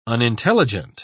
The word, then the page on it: unintelligent: British English